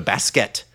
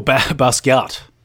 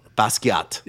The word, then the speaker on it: Basquiat: Like, J.J.'s doing the same thing with a basket jersey. He's wearing... Is that how you say it? Right? Basket? – I don't know what that is. – That's the artist who the Nets. – Basquiat. – Basquiat.